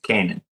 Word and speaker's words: Canaan: You can say it wrong if you want, but it's Canaan. Canaan, sorry, Canaan Police Department, and it drives off this dirt road that it's all covered in snow.